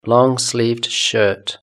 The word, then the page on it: longsleeved shirt: British English